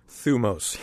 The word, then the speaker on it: thymos: In Greek, it's spelled T-H-Y-M-O-S. What is it? – Thumos, yes.